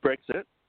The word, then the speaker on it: Brexit: Um, I wanted to ask you, how is Britics affecting by by this? Brit, uh, am I saying it right? Britics, Britics. – Brexit.